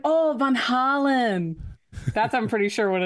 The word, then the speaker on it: Van Halen: Let's go to Kevin Brown, and this is the proper pronunciation. – Okay. – Apparently, it should be pronounced Van Halen. – Just like Kelsey said, Van Halen.